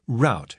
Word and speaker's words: route: By the way, the word route is pronounced route in U.S. English.